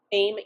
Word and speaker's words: Baim: Baim, yeah, Aim with a B.